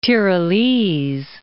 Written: Tyrolese

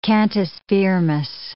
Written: cantus firmus

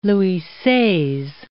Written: Louis Seize

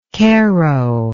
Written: kero

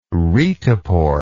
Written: retepore

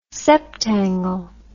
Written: septangle

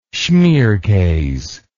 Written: schmierkase

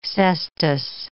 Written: cestus

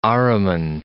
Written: Ahriman

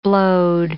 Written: blowed

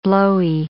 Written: blowy

